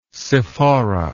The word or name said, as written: sephora